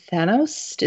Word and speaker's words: Thanos: No, they have been, for lack of a better word, Thanos.